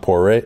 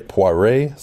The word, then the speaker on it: Poirier: Vincent Poirier, Poirier, sorry, you'd think my pronunciation would be better since I've been in uh, New France.